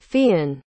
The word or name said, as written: fiynn